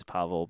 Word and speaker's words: Paweł: Pretty sure that Pawel is Pavel, but yeah.